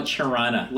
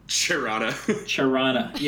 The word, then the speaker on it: Toronto: The people that American and listen to this don't understand that people from Toronto don't know how to pronounce their own city. – Toronto. – They call it Chirana. Like, Toronto. They say it with a C-H. – Chirana.